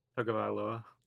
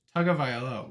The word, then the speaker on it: Tagovailoa: Tagovailoa. – Tagovailoa.